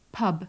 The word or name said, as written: pub